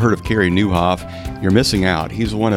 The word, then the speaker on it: Nieuwhof: Today, I'm so excited about our guest, Carrie Newhoff. The last name is spelled N-I-E-U-W-H-O-F.